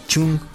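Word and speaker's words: chung (钟): pues resulta que despertador en chino se pronuncia chung la misma pronunciación que tiene la palabra muerte o final.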